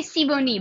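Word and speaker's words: Sibony: And Olivier Siboney? – I would say Siboney, but that's Siboney. My like years of French.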